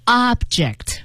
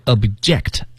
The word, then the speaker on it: object: Object，这是做动词的时候，当然大家一定也知道了，它还有一个读音啊，叫做 object。 – 对，那您仔细听哈、啊，做动词的时候是 object，而做名词的时候是 object。也就是说前面的重音往前提，而且后面那个 J-E-C-T 那个 E 要读成一个小 E 的音。